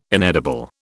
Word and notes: inedible: British English

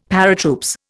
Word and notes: Paratroops: Indian

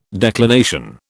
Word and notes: declination: British English

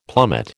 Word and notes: plummet: American English

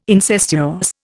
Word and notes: incestuous: Indian

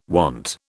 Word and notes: want: British English

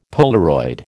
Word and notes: Polaroid: American English

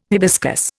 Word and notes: hibiscus: Indian

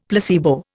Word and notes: placebo: Indian

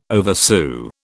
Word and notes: oversew: British English

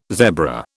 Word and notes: zebra: British English

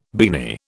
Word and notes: beanie: British English

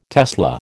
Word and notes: tesla: American English

